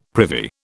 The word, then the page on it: privy: British English